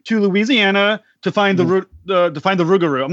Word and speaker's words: Rougarou: They pronounce it Rougarou, and it's just like the most amazing pronunciation. I can roll my R's because I'm from South Africa, so it's kind of a kind of guttural Afrikaans, but I love them.